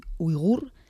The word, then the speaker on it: uigur: Pero pues una de las cosas que está ocurriendo es que la minoría, siempre lo pronuncio mal, pero porque digo yogur y no es uigur, está siendo maltratada incluso cuando se les dice que se está actuando por su bien, para que no se contagien.